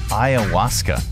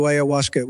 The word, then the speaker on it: ayahuasca: Ayahuasca.